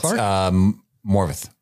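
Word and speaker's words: Morfydd: But really, my one performance to rule them all, because there can only be one, is how are we pronouncing her name now? Morvith? – It's Morvith.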